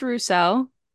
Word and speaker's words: Rousseau: The first one is just Rousseau.